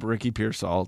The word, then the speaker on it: Pearsall: If you look up Ricky Pearsall, it's going to be, first of all, I got to make sure I'm pronouncing his name right. And I'm pretty sure that's right. – That's yes. – Because it's called Pear.